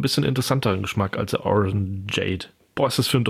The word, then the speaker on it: Orangeade: Wie Lemonade, nur mit Orange. Orangeade. – Orangeade, ja.